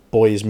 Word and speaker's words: buoy: It buoys me up. For Americans listening, I think you pronounce it buoy.